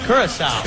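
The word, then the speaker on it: Curaçao: I wanted to Curacao. How you say that? – Curacao.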